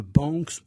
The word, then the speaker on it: Banks: Alors c'est le rosier de Banks Luthéa. Je le prononce à la française, c'est plus facile à mémoriser. Banks s'écrit B-A-N-K-S.